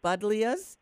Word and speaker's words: buddleias: Those are called buddleias, B-U-D-D-L-E-I-A. – Okay, spell it again. – B-U-D as in David, D as in David, L-E-I-A. Okay, buddleias.